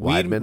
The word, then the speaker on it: Weidman: Prime Chris, how do we say his name? – Weidman. – Weidman, right? – Yeah.